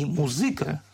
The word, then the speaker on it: музыка: это музыка и музыка с ударением на «ы».